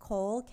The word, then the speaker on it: Koel: Is it pronounced Koel, K-O-E-L? – That's how I pronounce it.